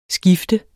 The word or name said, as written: skifte